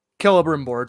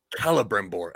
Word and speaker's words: Celebrimbor: Celebrimbor. It's always a hard C. – Celebrimbor.